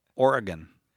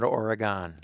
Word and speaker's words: Oregon: Kevin in Portland, Oregon. That's the correct pronunciation, you know. – Oregon. Oregon, yes. Not Oregon.